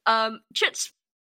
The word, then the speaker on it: chutzpah: Chutzpah? Words. – Ah. Chutzpah, I always thought. – Word is pronounced chutzpah.